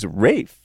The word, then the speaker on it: Ralph: Looks like Ralph, but it's actually pronounced Ralph. – I think it's Rafe, right?